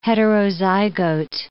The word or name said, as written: heterozygote